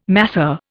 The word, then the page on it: masseur: Indian